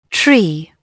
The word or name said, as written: tree